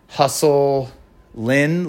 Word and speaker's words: Hustlin': I think it's – so it's spelled Hustlin', like insulin, I-N, Hustlin'. Anyway.